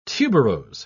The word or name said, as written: tuberose